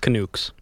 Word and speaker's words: Canucks: I believe that's pronounced Canucks.